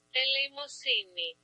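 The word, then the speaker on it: eleēmosunē: So the Greek word that I'm going to share with you today is for the word alms, and alms is A-L-M-S. So let me give you the word. – Elemosine.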